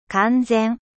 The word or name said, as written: complet